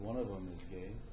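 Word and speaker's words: gay (γῆ): There's another word in the Greek which is pronounced gay. Actually, it's G-E, but it's a long E, so it's pronounced gay.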